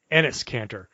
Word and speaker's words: Enes Kanter: Enes Kanter.